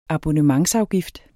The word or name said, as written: abonnementsafgift